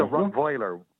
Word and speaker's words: Rottweiler: Rottweiler. – It's a Rottweiler. You pronounce it with a Z, don't you? – W in German is a V. Yeah. V sound.